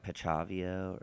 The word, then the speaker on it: Pichiavo: Is it like Pichavio or? – Oh yeah, I think that's correct. – Is that how you say it? – Yeah, I think it's like P-I-C-H-I-A-V-O.